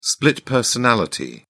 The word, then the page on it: split personality: British English